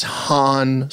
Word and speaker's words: Han: You know, somebody told Billy, someone said to Billy, it's pronounced Han. It's Han Solo.